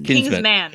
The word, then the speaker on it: Kingsman: He's saying it like Kingsman, but it's spelled Kingsman. – Oh, so it's Kingsman. – So it's singular or plural? – It's singular.